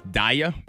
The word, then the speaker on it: Daya: That was Don't Let Me Down, Chainsmokers featuring, I guess it's Daya, D-A-Y-A. That's how you pronounce her name.